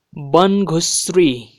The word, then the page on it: Banghushree: Nepali